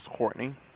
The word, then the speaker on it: Courtney: My name is Courtney. – May you spell it, please? – C-O-U-R-T-N-E-Y. – C-R-T. – C-O-U-R-T-N-E-Y.